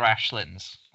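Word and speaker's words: aislings: I'll be honest, Ron, I had no idea how to pronounce this one at first. – Yes. – It's Irish, so it's The Midnight Court and Other Ashlins. So again, I would never have thought it was Ashlins, but yeah, it's Ashlins, that final word.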